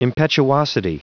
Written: impetuosity

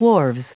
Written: wharves

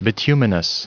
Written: bituminous

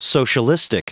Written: socialistic

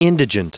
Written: indigent

